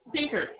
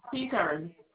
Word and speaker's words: Pinkard: You said P-I-K-A-R-D? – P-I-N-K, like the color pink? Pinker. P-I-N-K-A-R-D. Pinker.